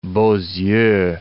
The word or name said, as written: beaux yeux